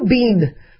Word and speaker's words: been: Now, how do you pronounce B-E-E-N? You know, like, Harry Potter, where have you been? I say been. I've been there. So it rhymes like sit.